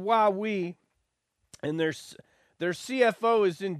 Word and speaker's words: Huawei: It's Huawei, like you would say when you were five and you were going down a slide and you'd say, Huawei!